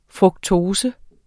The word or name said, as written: fruktose